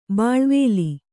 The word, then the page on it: ಬಾಳ್ವಲಿ: bāḷvali